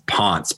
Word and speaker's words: Pontes: My name is spelled G as in George, G-E-O-F-F. Last name is Pontes, P-O-N-T-E-S, B-A.